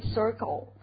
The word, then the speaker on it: circle: OK, 那么这个单词发音的时候大家也要注意，在美音当中只要字母含有 R, 那么我们需要卷舌 R, OK, circle.